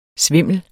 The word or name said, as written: svimle